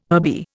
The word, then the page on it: herby: Indian